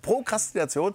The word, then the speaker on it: Prokrastination: Ein Thema, was dir auch sehr am Herzen liegt, ist die Prokrastination. Ja. Habe ich das jetzt richtig gesagt? – Ja, das hast du richtig gesagt. Prokrastination, das heißt, im Endeffekt heißt es, ich sag's mal so, Aufschieberitis.